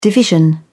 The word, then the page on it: division: British English